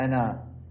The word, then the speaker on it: أنا: لا تقول أنا، هذه الألف ما تتلفظ في ضميري أنا، مر عليكم هذا في النحو صحيح؟